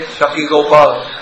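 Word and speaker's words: Sakshi Gopal: So sometimes we read in Chaitanya Charitamrita the story of Shakshi Gopal, or how do you say Shakshi Gopal? – Yes.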